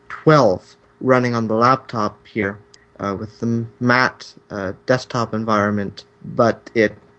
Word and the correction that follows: MATE: it's Mate, sorry.